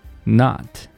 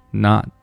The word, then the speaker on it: not: You're, you're, not. T 音我们只读一半，只做嘴型，不要发音。 Not, not.